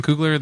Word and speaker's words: Coogler: Ryan Coogler, the director? – I think so, yeah.